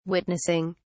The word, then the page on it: witnessing: British English